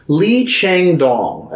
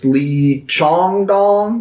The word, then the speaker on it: Lee Chang-dong: So we will be watching a South Korean director by the name of Lee Chang-dong, I believe is how you pronounce it. If I'm wrong, if it's Lee Chong-dong, that doesn't sound right. That sounds like I'm being racist. I'm pretty sure it's Lee Chang-dong.